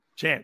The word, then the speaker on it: Chanz: Because they're probably going to forget to say Chanz, so like because of the Z. But I guess like it's, people are more comfortable saying Chance, so I just let them say Chance.